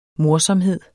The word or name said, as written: morsomhed